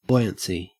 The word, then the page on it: buoyancy: Australian English